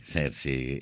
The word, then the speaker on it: Cersei: Cersei, io sono un povero Dortmund, che l'unica cosa che ho detto di bello nel Trono di Spade è stato...